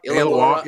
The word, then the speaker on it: Illawarra: And then I read, so I said, okay, well, and how do you pronounce that Australian team, that LaMelo played for? What's the name of the team he played for? – Illawarra? – Illawarra? – Illawarra? Yeah, okay. The Illawarra.